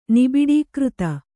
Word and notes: ನಿಬಿಡೀಕೃತ: kannada

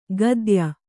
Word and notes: ಗದ್ಯ: gadya

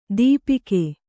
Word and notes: ದೀಪಿಕೆ: dīpike

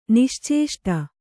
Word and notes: ನಿಶ್ಚೇಷ್ಟ: niścēṣṭa

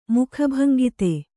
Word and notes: ಮುಖಭಂಗಿತೆ: mukha bhangite

